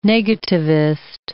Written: negativist

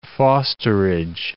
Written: fosterage

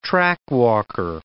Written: trackwalker

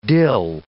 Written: dill

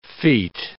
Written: feat

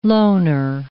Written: loaner

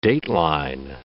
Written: dateline

